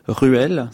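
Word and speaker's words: Reuel: Tolkien, c'est John Ronald Ruel, c'est comme ça qu'on prononce en anglais.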